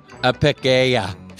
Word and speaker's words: Apicella: Apicella. – Amanda, let us know if we got that right. – Apicella. If you put a little Spanish on those two L's.